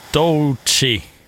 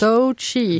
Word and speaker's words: Doechii: Runway med Lady Gaga och Doechii. Mm. – Ja, det var korrekt. Jättebra uttalat. – Tack. – Doechii.